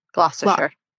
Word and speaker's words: Gloucestershire: Did I really mispronounce Gloucestershire? – Gloucestershire. – How do you say it? – Gloucestershire.